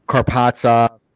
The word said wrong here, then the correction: Kaprizov: Right, that's that how you say his name, Kirill. – Kirill Kaprizov.